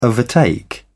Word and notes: overtake: British English

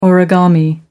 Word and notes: origami: American English

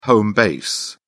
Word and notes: home base: British English

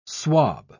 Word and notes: swob: British English

